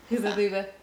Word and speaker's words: Hubba Bubba: Or if you're German, hubba bubba. Hubba booba. Yeah, apparently that's how you pronounce it in German. Hubba booba. – Hubba booba. – Because the U is an U. Sound.